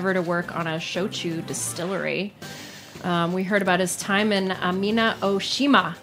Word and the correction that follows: Amami Oshima: I think that's how you pronounce it. I'm sorry, Amami Oshima, an island off the coast of Kagoshima, making Koyuto, which is black sugar.